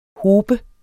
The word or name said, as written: hob